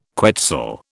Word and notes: quetzal: British English

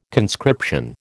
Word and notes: conscription: American English

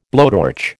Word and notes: blowtorch: American English

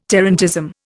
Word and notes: tarantism: Indian

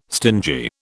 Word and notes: stingy: British English

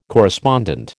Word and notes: correspondent: American English

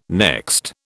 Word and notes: next: British English

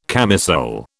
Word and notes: camisole: British English

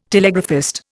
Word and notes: telegraphist: Indian

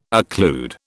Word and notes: occlude: British English

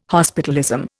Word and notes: hospitalism: Indian

